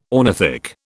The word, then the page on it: ornithic: British English